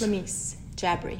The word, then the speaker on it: Lamis Jabri: Lamis Jabri.